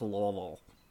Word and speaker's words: Louisville: A place spelled Louisville, but pronounced Louisville.